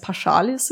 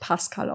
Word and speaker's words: Paschalis: Und beim Recherchieren habe ich es immer als Paschalis im Kopf gehabt. der heilige Paschal. Aber es wird Pascal ausgesprochen.